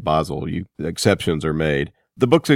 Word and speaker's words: Bahzell: Well, it's Bahzell.